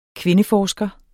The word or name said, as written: kvindeforsker